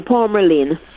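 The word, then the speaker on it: Palmerlyn: Palmerline.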